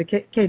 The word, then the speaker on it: Kae: So if you go to our show, GoPro Radio, on GoPro Radio, and then just type in the K Thompson Live. – Mm-hmm. – My first name is spelled, by the way, everyone, K-A-E.